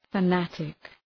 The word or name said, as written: fanatic